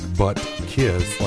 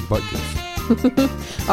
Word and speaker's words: Butkus: Is it Butkus? – It is. I mean, you're saying it like butt kiss. Like yeah. It's two words. It's one word, Butkus.